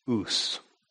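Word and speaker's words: ous (οὖς): The common word in the Greek language for ear is pronounced ous. Three letters. It's, if you were transliterating it into English, it'd be O-U-S.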